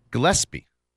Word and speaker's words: Gillespie: Uh, Gillespie. Did I say that right? I think it's S before P. You say it. Gillespie. Gillespie. – Yeah. Gillespie.